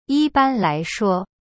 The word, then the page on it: 一般来说: yi ban lai shuo